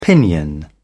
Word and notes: pinion: British English

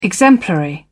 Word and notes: exemplary: British English

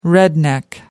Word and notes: redneck: American English

